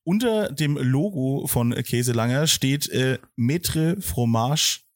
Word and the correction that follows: Fromager: Affineur. Habe ich richtig ausgesprochen? – Maître Fromager Affineur. – Ähm, Fromager, na ja.